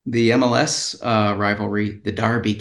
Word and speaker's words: Derby: Yeah, but it's spelled with an E. And they call it Darby, so go figure.